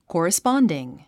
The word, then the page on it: corresponding: kɔ̀ːrəspάndiŋ; コーラスパンディン